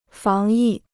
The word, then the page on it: 防疫: fáng yì